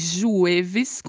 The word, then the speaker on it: jueves: Hoy es jueves. Jueves, quinto día de la semana. Escribe-se jueves con J y V. J tem leve som de R, V tem leve som de B. Então pronunciamos jueves. Jueves.